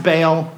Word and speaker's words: Baal: It's spelled B-A-A-L, sometimes pronounced Baal or Baal.